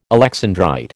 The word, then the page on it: alexandrite: American English